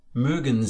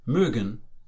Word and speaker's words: mögen: Mögen Sie. That's do you like. This is an example of the verb mögen, to like. Note the pronunciation. We have an Ö with its own distinctive sound.